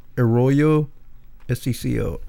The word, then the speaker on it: Arroyo Seco: Am I saying that right, Doc? It's in California, I believe. A-R-R-O-Y-O. Arroyo S-E-C-O. Arroyo Seco Canyon. – Yeah, it sounds about right.